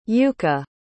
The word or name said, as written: Yuka